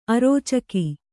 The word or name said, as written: ಅರೋಚಕಿ